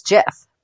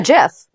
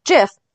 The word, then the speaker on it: GIF: However, the inventor says it's GIF. – Okay. – In 2013, he won a Webby Award, and they only get 5 words to like accept the award. And so he made a GIF with the word saying it's pronounced GIF, not GIF.